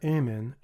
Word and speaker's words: Amen: The word Amen should be pronounced as though it were spelled A-M-N, or really A-M, and as one syllable rather than two. If it were spelled A-H-M-N, we would be able to better pronounce it, for the A should have a fairly broad sound given to it.